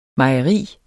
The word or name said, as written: mejeri